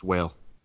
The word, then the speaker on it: Wale: You're just saying his name wrong. It's Whale. – It's Wale. – I don't think so. – Like Wally.